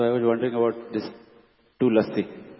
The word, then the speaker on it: Tulasi: It is la word is full, so it will be actually pronounced as Tulasi. But when we say Tulsi, la word is pronounced only half. – So Tulsi is not correct.